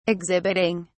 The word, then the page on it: exhibiting: British English